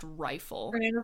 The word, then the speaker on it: Reighfyl: It's like R-E-I-G-H-F-Y-L, pronounced rifle.